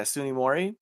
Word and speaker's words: Tsunemori: Her name is Akane Tsunemori, which I always pronounce Tsunemori? – T-S-U-N-E, yeah, Tsunemori.